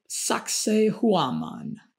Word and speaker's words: Sacsayhuaman: Moving on to Sacsayhuaman. I'm trying to pronounce that in a in a Peruvian way.